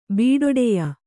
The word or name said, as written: ಬೀಡೊಡೆಯ